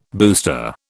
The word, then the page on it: booster: British English